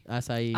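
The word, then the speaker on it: açaí: Acai. – Acai.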